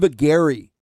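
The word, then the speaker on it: Augeri: So Steve Aguirre, which rhymes with Stephen Perry.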